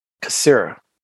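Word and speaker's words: caesura: But it lacks a C-A-E-S-U-R-A with a Syria? Kasira? Kasira. Yes, Kasira.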